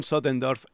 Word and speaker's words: Sottendorff: Sotterdam, y al final lo he dicho mal, ¿te has dado cuenta? Con lo bien que iba. Los delirios de Von Sottendorf y su mente cuadriculada.